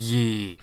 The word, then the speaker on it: Ye: Oh, that's why his company is called Ye. – It's actually pronounced Ye.